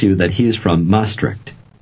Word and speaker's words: Maastricht: Maastricht. Maastricht.